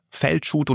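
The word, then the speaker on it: Felcsút: Wie spricht man den Ort aus? – Feldschut, und es Feldschut ist ein Dorf von ca.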